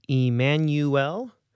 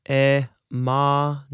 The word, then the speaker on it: Emmanuel: The only big thing to watch out for in the pronunciation here is, in English we say Emmanuel. When we're chanting in Latin, we say Emmanuel, Emmanuel, Emmanuel.